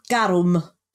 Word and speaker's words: garum: Yeah, no, it's pretty much just garum. It's exactly the same, just with a roll dar.